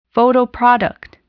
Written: photoproduct

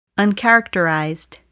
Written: uncharacterized

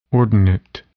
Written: ordinate

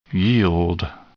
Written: yield

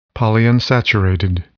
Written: polyunsaturated

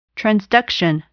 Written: transduction

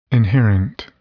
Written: inherent

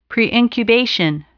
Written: preincubation

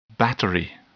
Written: battery

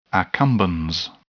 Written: accumbens

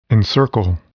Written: encircle

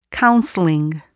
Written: counseling

